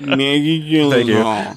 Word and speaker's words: Gyllenhaal: Say it right. Maggie Gyllenhaal.